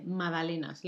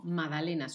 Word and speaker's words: magdalenas: Se escribe con G, pero la G no se pronuncia nunca. Magdalenas. Como el nombre Madalenas, la G no se pronuncia, se escribe,